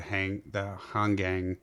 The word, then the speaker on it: Hangang: I think you've got the brand new Aquas Green Dial, and I'll probably completely screw up the pronunciation, but I think it's the Hangang River. – Correct.